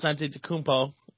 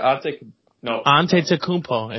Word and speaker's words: Antetokounmpo: But I think to say that Giannis Antetokounmpo, and that's how you say it. – I can't say his last name. Wait, Antetokounmpo.